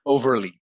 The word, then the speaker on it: Overlie: Did I say it right? – That's Overlie.